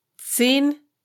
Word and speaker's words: Zehn: Z, like in zebra, is pronounced TS in German, and every single word in this tongue twister starts with Z. Zehn zahme Ziegen ziehen zehn Zentner Zucker zum Zoo.